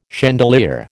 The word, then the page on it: chandelier: American English